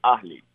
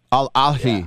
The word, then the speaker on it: Ahly: It's not Al Ahly? – No, it's H, so Ahly. – Ahly. Al Ahly.